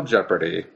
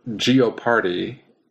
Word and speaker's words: Jeopardy: If it was Final Jeopardy, or Geoparty, if you say it the way it's spelled, nobody would say it that way.